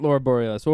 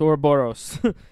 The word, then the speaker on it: Ouroboros: Not Laura Borealis. Ouroboros. As far as I know how to pronounce, I don't really exactly know. I don't know if it's our O because it's spelled O U R, but whatever.